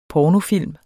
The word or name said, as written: pornofilm